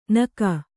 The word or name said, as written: ನಕ